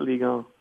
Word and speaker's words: Ligue 1: One is UN, so they pronounce it Ligue 1.